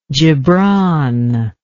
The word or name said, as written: gibran